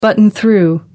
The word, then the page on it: button through: American English